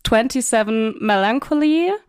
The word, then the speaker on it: 27 Melancholy: Wie ist die korrekte Aussprache? Ist es 27 Melancholy? – Ja, genau.